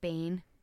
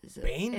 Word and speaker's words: Baine: I thought Wayne was like Bane. Bane in Scotland. – Bane? – Is a Wayne. – Bane. – But it's spelled like. – Wayne. – B-A-I-N-E.